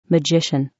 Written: magician

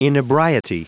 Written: inebriety

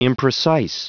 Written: imprecise

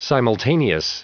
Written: simultaneous

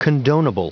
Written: condonable

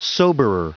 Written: soberer

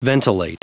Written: ventilate

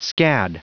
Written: scad

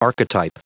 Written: archetype

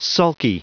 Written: sulky

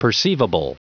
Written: perceivable

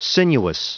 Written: sinuous

